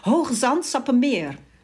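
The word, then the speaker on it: Hoogezand-Sappemeer: Und wie spricht man den Ort aus dem du geboren wurdest? – Ja, Hogezand-Sappemeer.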